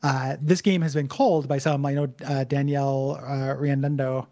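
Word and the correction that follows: Riendeau: I mispronounced her last name, right? – Riando. Riando. – Yeah, Riando.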